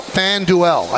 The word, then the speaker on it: FanDuel: But first, Peter's going to tell us about FanDuel. I think that's how you pronounce it. – That is correct.